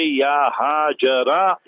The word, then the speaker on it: Hajar: Tarkhim means you use the name and the last letter you remove it, you drop it. And you know, you don't pronounce it. So you say, Ya Hajara, Ya Hajar. For Sayyida Hajar radiallahu anha. So both ways are permissible. Hajar and Hajara.